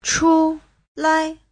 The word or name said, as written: chu5lai5